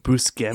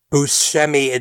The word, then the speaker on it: Buscemi: Buscemi? – He gets introduced as Buscemi, but when the topic gets brought up, Buscemi admits that while that pronunciation is correct, so is Buscemi.